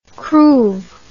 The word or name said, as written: cruive